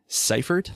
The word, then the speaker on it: Seifert: Seifert. Last name is spelled S-E-I-F-E-R-T. I'm on Twitter. It's @ Brandon T. Seifert. S-E-I-F-E-R-T.